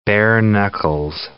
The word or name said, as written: bare-knuckles